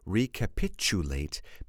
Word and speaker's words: recapitulate: But I want you to pronounce this word recapitulate because you can hear this CH sound, but the CH doesn't exist.